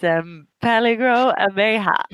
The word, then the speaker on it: Peligro Abeja: It's Peligro Abeja. – Oh, that is correct. And that was a fine pronunciation.